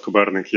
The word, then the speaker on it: Kobernyk: How's my Ukrainian talking about your last name or pronouncing your last name? – It was pretty close, Taras Kobernyk. Yeah, excellent, mate.